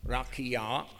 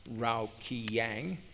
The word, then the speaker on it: Rakia: The explanation to facsimile 2, figure 12 reads, and I'm going to pronounce this correctly, Rakia, not Raukiyang.